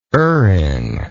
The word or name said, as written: erringly